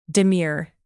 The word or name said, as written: demure